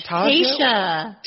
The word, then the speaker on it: Taysia: So, Tasia. – No. Tasia. – Tasia. – You always are like, what are you saying? Tasia. – Tasia. Well, it's spelled T-A-Y-S-I-A, right? – I guess it's pronounced Tasia. Tasia.